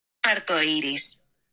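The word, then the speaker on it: arcoíris: Arcoiris.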